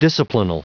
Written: disciplinal

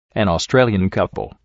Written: an australian couple

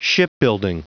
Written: shipbuilding

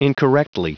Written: incorrectly

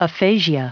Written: aphasia

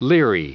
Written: leery

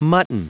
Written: mutton

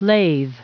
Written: lathe

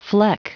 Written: fleck

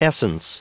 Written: essence

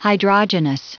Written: hydrogenous

